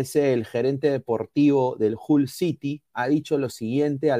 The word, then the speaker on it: Hull City: Hull City, así se pronuncia en inglés, Hull City, no FC, de la Championship de la liga inglesa.